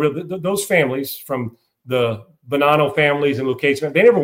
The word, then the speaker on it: Bonanno: Mafia, Lucchese family, the Bonino family. That's Bonanno, I guess is how it's pronounced.